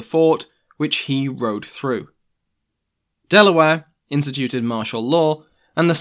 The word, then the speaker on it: de la Warr: Reinforcements and supplies had arrived, with the governor himself, Thomas West, the 3rd Baron de la Warr. Delaware, which is how I'm going to be pronouncing his name, forced the survivors to turn around.